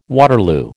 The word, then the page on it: Waterloo: American English